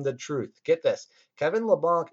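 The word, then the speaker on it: Leblanc: For my next forward streamer pick, we've got Kevin LeBanc of the San Jose Sharks. And first off, I know it's pronounced LeBanc, but it's a zillion times funner to call it LeBanc.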